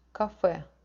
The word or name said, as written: kafe